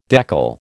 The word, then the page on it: deckle: American English